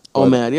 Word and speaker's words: OMAD: How do you say OMAD? – OMAD. Yeah, we're right.